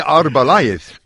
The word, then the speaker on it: Arbeláez: Well, Andre Arbeláez. If you want to get the Z would be a TH.